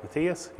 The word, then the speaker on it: Matthias: Matthias.